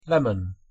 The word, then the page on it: lemon: English